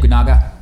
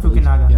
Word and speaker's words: Fukunaga: The guy did Fukunaga. – Is it Fukunaga? – Fukunaga. – Yeah, Fukunaga.